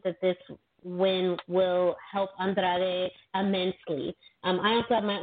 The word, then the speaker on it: Andrade: On the kickoff show, we have Andrade going one on one with Rey Mysterio. We'll let you go first, but can you properly pronounce Andrade? – Andrade. – Yeah, right. Perfect.